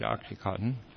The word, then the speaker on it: OxyContin: Actually, I've had people on this show talking about the criminalization of pain medications, like heroin, but also like OxyContin. What's the proper phrase? – Yeah. – I'm not getting the pronunciation properly. – No, but you got it right, OxyContin.